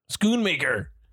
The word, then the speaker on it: Schoonmaker: For an almost 40-year-old movie, the restoration work that was it was supervised by Scorsese and Thelma Shoemaker. – Yeah. – And is that how you say her name? – Sure. Yeah. Shoemaker, yeah. Could be Schumacher, but Schoonmaker. Thelma, sorry.